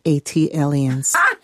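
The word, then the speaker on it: ATLiens: That is pronounced Atlians. – Thank you. – Like Atlanta alien.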